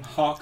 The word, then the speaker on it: Hauck: Hauck. – Hauck. Yep. Just like, like the bird.